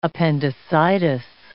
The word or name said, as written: appendicitis